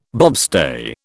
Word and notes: bobstay: British English

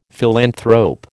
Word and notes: philanthrope: American English

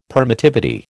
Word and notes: permittivity: American English

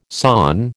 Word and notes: sawn: American English

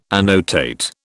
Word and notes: annotate: British English

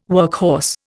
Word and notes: workhorse: Indian

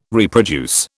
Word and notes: reproduce: British English

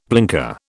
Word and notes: blinker: British English

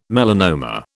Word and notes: melanoma: British English

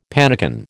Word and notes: pannikin: American English

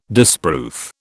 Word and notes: disproof: British English

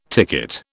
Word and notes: ticket: British English

